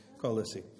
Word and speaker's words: Colossae: Lisa, do you remember how do I say Colossia in Colossae? How do you say Colossae? Okay, there we are. Anyway, an aside there, just helping me with my English.